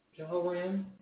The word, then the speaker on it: Jehoram: Jehoram.